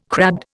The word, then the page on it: crabbed: Indian